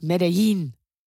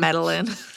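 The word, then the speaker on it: Medellín: I think it's pronounced Medellin. – Yeah, there's an accent. There's two L's. Yeah. – Madeline. – Not Madeline.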